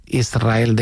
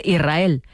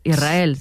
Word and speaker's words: Israel: Y la S con la R es difícil de pronunciar. La gente dice Israel, sin que suene la S. Israel, sí, es difícil de pronunciar.